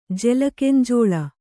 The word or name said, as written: ಜೆಲಕೆಂಜೋಳ